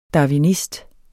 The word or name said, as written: darwinist